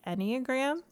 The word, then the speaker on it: Enneagram: How did your Myers-Briggs work, or did it, how did that lead you to the, is it the Enneagram? Am I saying that right? – Yes.